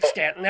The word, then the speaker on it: Staunton: But in Virginia, Staunton is pronounced Stan.